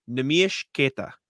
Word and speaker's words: Neemias Queta: Bobby Manning and I were at his event in Quincy, and Bobby asked him, "How do you pronounce your name?" Neemias Queta. That is the name.